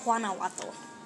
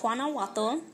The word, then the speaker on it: Guanajuato: Guanajuato, and fought and fought off the Spanish defense of, it's probably Guanajuato. Guanajuato. Because I'm pretty sure it's a soft G.